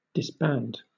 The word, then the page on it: disband: British English